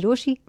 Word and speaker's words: Rochie: मेरा नाम है मिर्ची रोशी, नाम की स्पेलिंग है ROCHIE।